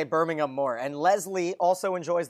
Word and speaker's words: Birmingham: but makes fun of us because she says the H is silent. It's just Birmingham.